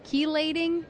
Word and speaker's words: chelating: So in looking then at some of the other products, the other one was chelating. Am I pronouncing that correctly? – Yes.